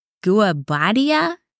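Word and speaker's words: Guobadia: But Guabadia, is that how you pronounce it? – Close enough. Close enough.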